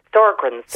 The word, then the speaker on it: Sjögren's: Oh um, Sjögren's syndrome. sorry, I'm pronouncing it wrong.